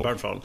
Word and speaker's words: Bernthal: Som spelas av John Burntroll. Hur fan man uttalar hans Berntroll. – Burntroll. Bernthal.